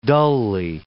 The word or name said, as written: dully